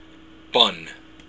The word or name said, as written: bun